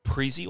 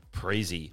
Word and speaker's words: Preezie: The founder and CEO of Preezie or Preezie. Preezie. Preezie. That's it.